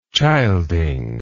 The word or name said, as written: childing